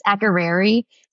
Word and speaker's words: Akureyri: It's Akureyri.